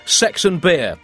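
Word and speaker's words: seksen bir: 81 in Turkish, well, it's still 81, but it's pronounced sex and beer.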